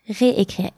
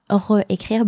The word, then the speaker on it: réécrire: Réécrire. Écrire starts with vowel sound. Donc, not réécrire, but réécrire.